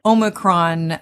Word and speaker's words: Omicron: It's all about the Omicron latest variant.